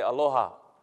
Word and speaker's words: aloha: And so from where we come from, we say aloha. Aloha.